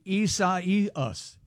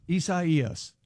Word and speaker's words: Isaias: Just to make it's like Osiasis. – That's very, very close. It's Hurricane Isaias. Isaias.